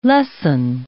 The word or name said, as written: lesson